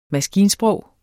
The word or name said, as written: maskinsprog